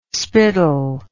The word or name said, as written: spittle